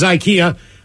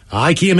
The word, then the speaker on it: IKEA: Subject is IKEA. IKEA made the announcement probably,